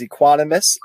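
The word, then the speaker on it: Equanimeous: I believe how you pronounce his first name is Equanimous and last name St. Brown, who was on injured reserve for the whole first half of the season.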